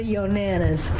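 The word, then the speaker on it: Yonanas: Yeah, it's Y-O-N-A-N-A-S, Yo Nanas.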